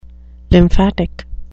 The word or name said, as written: lymphatic